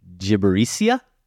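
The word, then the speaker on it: Gibberitia: Now, Josh, I did some work here to try to figure out how to pronounce this word. It's Ernest and Celestine, A Trip to Gibberisia. That's what I heard online. – I'm going to say you're correct.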